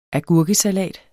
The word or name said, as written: AGURKESALATEN